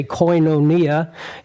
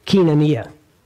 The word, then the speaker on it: koinonia: We say koinonia, and they say kinonia. It always just flows so much easier.